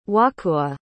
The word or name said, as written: wahkoowah